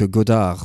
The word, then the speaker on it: Godard: Jean-Luc Godard, voilà. Je savais que c'était en deux syllabes, mais ça m'avait échappé.